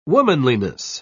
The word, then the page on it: womanliness: British English